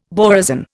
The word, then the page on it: borazon: Indian